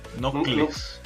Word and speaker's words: Knuckles: Espérate, espérate, ¿cómo se dice? Corregime. Knuckles. Knuckles. Ah, ya. – Knuckles. Nunca supe de chiquito, me confundía la K con la N.